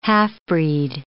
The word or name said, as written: half-breed